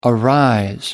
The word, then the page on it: arise: American English